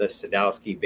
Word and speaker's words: Sadowski: And although I'm sure I'm gonna butcher this name too, why is the Sadowski bass your choice to play often? – You nailed that. You could be in a Roger Sadowski ad.